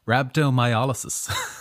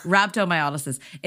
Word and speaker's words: rhabdomyolysis: Rhabdomyolysis. – Rhabdomyolysis.